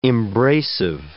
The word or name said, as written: embracive